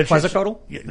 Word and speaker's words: Quetzalcoatl: And so every culture starts human sacrifice to Moloch or to, you know, the winged snake, it's hard to pronounce, Quetzalcoatl or whatever. – Yeah, one of those. – With the Aztecs. And I, you know, I've been to Quetzalcoatl? – That's how you say it.